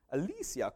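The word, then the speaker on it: Alessia: and I think I'm saying this right, Alyssa Cara? Alyssa? Alyssa? Alyssa? Alyssa Cara? Alicia! Alicia Cara.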